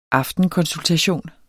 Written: AFTENKONSULTATION

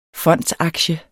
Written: FONDSAKTIEN

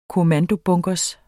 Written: kommandobunker

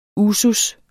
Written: usus

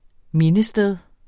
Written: mindested

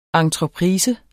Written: ENTREPRISE

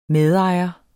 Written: medejer

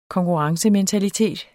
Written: konkurrencementalitet

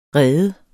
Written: rede

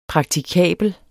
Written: praktikabel

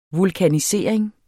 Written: vulkanisering

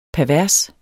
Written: pervers

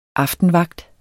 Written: aftenvagt

